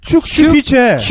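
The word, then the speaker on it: Zugspitze: 쭈꾸슈피체가 야, 발음 그 좀, 야, 쭉, 뭐, 아씨. 축 슈피체. 축 슈피체.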